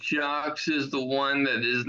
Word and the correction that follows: Jacques: You don't say the S. You don't pronounce the S. It's Jock.